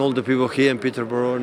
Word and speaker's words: Peterborough: Peterborough.